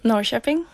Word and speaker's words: Norrköping: Norrköping, do you call it? – Norrköping. – Norrköping. – Yeah, right, okay. – Close. – Great Swedish pronunciation, yeah.